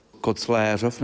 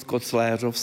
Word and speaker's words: Koclířov: A také jsem si všiml, že tato obec Koclérov nebo Koclířov má vlastně dvojí výslovnost. A já se zeptám pana badatele Hladkého, jak to tedy je. – Původně se to německý za rakouských dob, že jo, císařů se používalo Kecesdorf pro tuto oblast a dole byla, co je Fatima, neboli Koclířov, se používal název správný Kecesdorf Schengentau. – A v současné době se ustálila tedy výslovnost Koclérov s E.